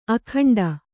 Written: ಅಖಂಡ

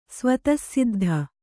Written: ಸ್ವತಃಸಿದ್ಧ

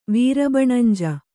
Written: ವೀರಬಣಂಜು

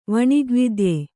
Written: ವಣಿಗ್ವಿದ್ಯೆ

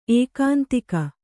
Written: ಏಕಾಂತಿಕ

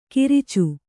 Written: ಕಿರಿಚು

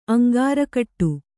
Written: ಅಂಗಾರಕಟ್ಟು

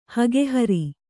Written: ಹಗೆಹರಿ

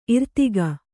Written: ಇರ್ದುಗ

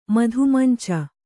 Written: ಮಧುಮಂಚ

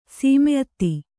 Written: ಸೀಮೆಅತ್ತಿ

